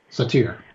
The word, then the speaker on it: Satir: About authenticity and about Virginia Satir, how do you say her name? – Satir. – Satir.